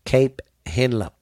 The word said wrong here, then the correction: Cape Henlopen: Cape Henlopen.